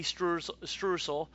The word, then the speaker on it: streusel: I can never – and then a safari sunset, which is a carrot cake, cornbread, calamansi, a lemon, crumps, cognac coffee, streusel. And that ain't strudel. It's a streusel is the way they're pronouncing it. It's streusel.